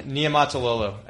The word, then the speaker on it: Niumatalolo: Niumatalolo, I think that's right.